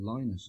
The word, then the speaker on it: Lynott: Or he's meant to be called Lynott, actually. That's his the correct pronunciation, but I have no recall of how it came about.